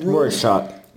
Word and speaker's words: Rorschach: Or what he also did, for those who have read Jordan Peele interviews, he says, well, it's sort of a Rorschach, how do you say it? – Rorschach.